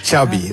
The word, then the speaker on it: Xavi: Is the correct pronunciation of your first and last name? – Xavi Hernandez. – Xavi, okay. – Yeah. – So I heard that Xavi like chocolate.